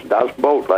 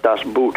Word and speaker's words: Das Boot: It's Das Boot, like you said. It's not Das Boot. It's not a piece of footwear. – No, it is. It's Boat.